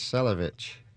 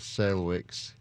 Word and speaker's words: Salewicz: Chris Salewicz.